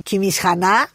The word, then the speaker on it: Κιμισχανά: Την Αργυρούπολη την ονοματίζαν και Κιμισχανά, στα ποντιακά με την προφορά την ποντιακή. Είναι Κιμισχανέ η τουρκική, δηλαδή Αργυρούπολη, α ναι, Ναι.